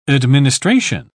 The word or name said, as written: administration